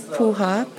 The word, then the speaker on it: Puha: My first name is Dionne, D-I-O-N-N-E, and my last name is Puha, P-U-H-A.